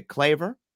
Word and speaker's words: Claver: And then of course, Friday, St. Peter is a claver.